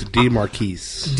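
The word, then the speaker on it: De'Marquise: D E apostrophe M-A-R-Q-U-I-S-E Eakins. – DeMarcus Eakins. – I believe is the gentleman's name. So I mean that sounds French to me. – I believe it's DeMarquise.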